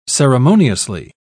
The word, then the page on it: ceremoniously: British English